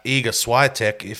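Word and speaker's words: Iga Świątek: Even though right now you can tell that Iga Swiatek, I'm not sure if I said her name right.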